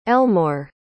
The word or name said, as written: Elmoor